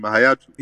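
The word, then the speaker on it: Hayatou: You know, Isa Hayatu, for those, how do you say his name? Hayatu. – Yeah, Isa Hayatu.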